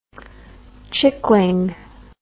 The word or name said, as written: chickling